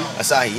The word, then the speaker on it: Asahi: And that's how you say it, Asahi? – Asahi, yeah. Okay.